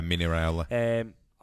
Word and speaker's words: Raiola: Jonathan Barnett, like the super agent, and Mino Raiola, is that how you say his name? – Yeah, Mino Raiola.